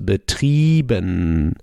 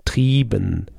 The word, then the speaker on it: betrieben: And we have the IE that is pronounced like a long I. So it's pronounced betrieben. Betrieben, betrieben.